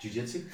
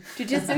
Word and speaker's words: jiu-jitsu: Yeah, and then did did you did how do you say that? Jiu-jitsu. Jiu-jitsu.